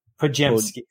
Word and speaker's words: Podziemski: Podziemski. – Podziemski? – Yeah. Brandon Podziemski.